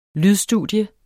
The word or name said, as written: lydstudie